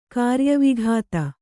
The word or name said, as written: ಕಾರ್ಯವಿಘಾತ